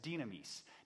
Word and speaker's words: dynamis: And this word for power here is dynamis. Dynamis. And it's spelled D-Y-N-A-M and then I-S.